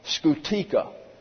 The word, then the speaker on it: scutica: Milder forms was the scutica. S-C-U-T-I-C-A. The scutica. That was simply a leather whip whip, smaller variety. Werga, V-I-R-G-A.